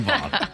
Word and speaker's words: Vaughn: My last name rhymes with sloth. – This is Austin Vaughn.